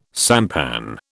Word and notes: sampan: British English

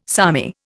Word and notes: salmi: Indian

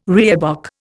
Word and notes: reebok: Indian

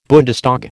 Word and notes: Bundestag: American English

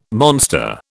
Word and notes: monster: British English

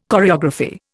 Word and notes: choreography: Indian

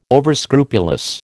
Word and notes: overscrupulous: American English

